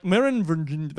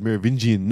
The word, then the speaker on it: Merovingian: But like, Merovingian, that guy, right?